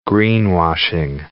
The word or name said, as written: greenwashing